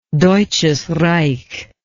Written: Deutsches Reich

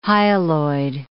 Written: hyaloid

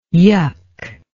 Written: yuck

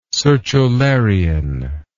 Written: sertularian

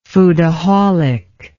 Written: foodaholic

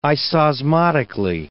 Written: isosmotically

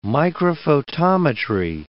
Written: microphotometry

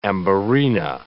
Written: amberina

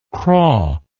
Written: craw

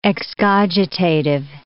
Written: excogitative

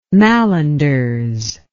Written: mallenders

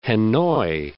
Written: Hanoi

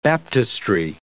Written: baptistry

